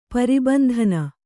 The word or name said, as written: ಪರಿಬಂಧನ